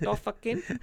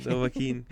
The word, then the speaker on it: Dovahkiin: Dovahkiin? – I mean, yeah, that works. – I don't know how to pronounce it. Dovahkiin guy. – Dovahkiin.